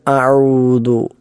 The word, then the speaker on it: أعوذ: ഒന്ന് അഊദു, അഊദു, അത് ശ്രദ്ധിക്കേണ്ടതായ 3 ഒന്നാമത്തെ അതെല്ലാവരും ശരിയാക്കാറുള്ളതാണ്, ആർക്കും തെറ്റില്ലാത്ത ഒരു കാര്യമാണ്, അക്ഷരമാണ്. രണ്ടാമത്തേത് ഐനാണ്, അഊ, അഊ എന്ന ഐൻ തൊണ്ടയുടെ മധ്യഭാഗത്ത് നിന്നും പുറപ്പെടേണ്ട അക്ഷരമാണ്.